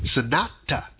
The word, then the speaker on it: Sanat: I'm sorry, Sanata. It's spelled S-A-N-A-T, Kumara, K-U-M-A-R-A. All right?